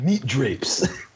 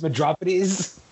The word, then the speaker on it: Medropides: Who here has got meat drapes? Actually, it's Medropides. It's Greek.